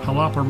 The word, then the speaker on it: Jalapa: Jalapa regions, yes.